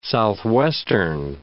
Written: southwestern

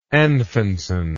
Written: Anfinsen

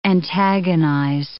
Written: antagonize

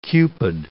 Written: Cupid

